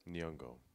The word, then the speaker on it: Nyong'o: Nyong'o. – Nyong'o. – Lupita Nyong'o.